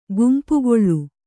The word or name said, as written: ಕಂಪುಗಾಳಿ